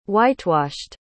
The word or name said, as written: whitewashed